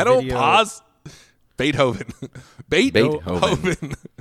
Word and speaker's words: Beethoven: Sorry, I was listening to a video that says how to say Beethoven.